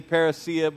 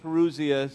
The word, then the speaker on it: parousia: The Greek word that is used for this coming, Perusia, some would, pay, would say parousia, but Perusia is the, the better pronunciation.